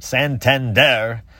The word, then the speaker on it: Santander: Santander.